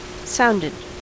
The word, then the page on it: sounded: American English